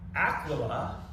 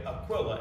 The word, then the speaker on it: Aquila: Aquila, I pronounce it, and his wife Priscilla. Some people say Aquila and Priscilla, but I prefer the former pronunciation.